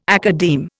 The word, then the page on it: academe: Indian